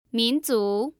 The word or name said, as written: minzu